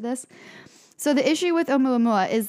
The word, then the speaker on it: Oumuamua: I looked up the pronunciation. It's Oumuamua. Pretty sure is how you say it.